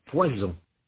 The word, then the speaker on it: poison: Un poisson, un poison. Un dessert, un désert. – Notez la différence de prononciation. Il ne faut pas confondre poisson et poison.